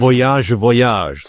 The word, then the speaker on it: Voyage: Und damit sind wir in Frankreich gelandet, nämlich bei Désirless und Voyage. Oder Voyage, so muss man es natürlich betonen, ganz klar.